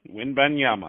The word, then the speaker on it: Wembanyama: Wembanyama. Wembanyama.